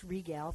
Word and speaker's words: Rigel: My son is Rigel, which is often pronounced Regal from Orion.